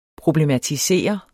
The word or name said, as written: problematisere